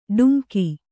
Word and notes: ಡುಮ್ಕಿ: ḍumki

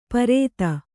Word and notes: ಪರೇತ: kannada

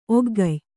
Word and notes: ಒಗ್ಗಯ್: Kannada

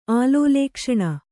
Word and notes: ಆಲೋಲೇಕ್ಷಣ: Kannada

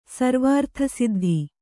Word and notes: ಸರ್ವಾರ್ಥಸಿದ್ಧ: sarvārtha siddha

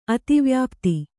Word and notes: ಅತಿವ್ಯಾಪ್ತಿ: ativyāpti

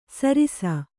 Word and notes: ಸರಿಸ: Kannada

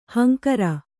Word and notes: ಹಂಕರ: hankara

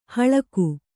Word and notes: ಹಲ್ಕ: halka

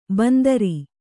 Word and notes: ಬಂದರಿ: bandari

